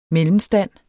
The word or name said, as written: MELLEMSTANDS